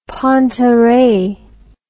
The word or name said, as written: pantarhei